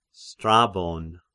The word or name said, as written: Strabon